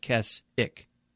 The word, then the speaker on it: Keswick: So I think that's what the way it is with Keswick is pronounced Keswick.